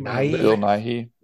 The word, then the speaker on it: Nighy: Bill Nighy.